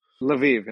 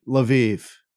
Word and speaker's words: Lviv: Lviv? – Lviv in Western Ukraine. – Lviv.